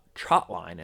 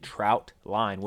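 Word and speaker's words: trotline: And then as I came to understand, most people are saying it wrong. They're saying trout line, which is a misnomer. It is just a misunderstanding of what is actually called. It is a trot.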